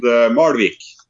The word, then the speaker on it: Malvik: The small town named Malvik.